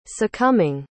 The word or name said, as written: succumbing